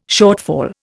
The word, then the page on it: shortfall: Indian